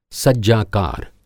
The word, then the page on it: सज्जाकार: Hindi